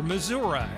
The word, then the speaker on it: Missouri: first Showbiz Pizza Place opens in Kansas City, Missouri, or Missouri, as some of the natives pronounce it.